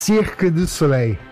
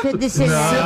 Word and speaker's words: Cirque du Soleil: Cirque du Soleil. – Tá, eu falei certo. – Cirque du Soleil. – Não, du Soleil. – Não falou não. – Cirque du Soleil.